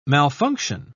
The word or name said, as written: malfunction